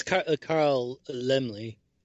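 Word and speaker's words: Laemmle: It's Carl Zemele. – Yes. – And You put two letters like that and expect me to know how to say it. – Let me tell you. – It's German.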